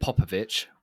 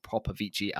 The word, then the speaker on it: Popovici: Say David Popovich. We have been corrected. He was on the Brett Hawke podcast, and that is how you pronounce his name, not Popovici as it looks.